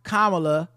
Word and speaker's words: Kamala: Pronounced Kamala Jamaica Harris.